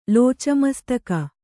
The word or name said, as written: ಲೋಚಮಸ್ತಕ